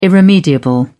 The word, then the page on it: irremediable: British English